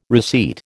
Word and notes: receipt: American English

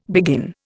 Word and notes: begin: Indian

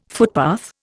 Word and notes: footpath: Indian

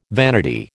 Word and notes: vanity: American English

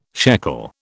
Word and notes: shekel: British English

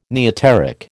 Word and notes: neoteric: American English

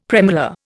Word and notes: premolar: Indian

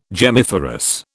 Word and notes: gemmiferous: British English

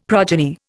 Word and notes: progeny: Indian

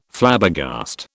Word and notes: Flabbergast: British English

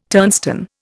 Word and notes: turnstone: Indian

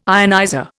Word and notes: ionizer: Indian

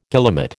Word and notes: guillemot: American English

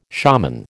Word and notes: shaman: American English

